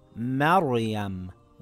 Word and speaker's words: Maryam: This example has a sukun on the Ra. The letter before it has a fatha. It will read as Maryam, with a heavy Ra.